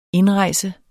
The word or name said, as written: indrejse